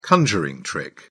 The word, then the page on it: conjuring trick: British English